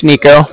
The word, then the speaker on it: Niko: So, yeah, like I said, my name's Nico. That's spelled N-I-K-O. And then the Bank of Cardiff is spelled C-A-R-D-I-F-F, as in Frank Frank.